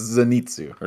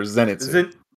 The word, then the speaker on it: Zenitsu: Zenitsu. – Wrong. Super wrong. It's Zenitsu or Zenitsu.